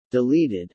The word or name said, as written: deleted